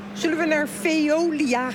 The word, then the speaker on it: Veolia: Want wij gaan zo naar Veolia, zeg ik dat goed? – Ja, dat zeg je heel goed.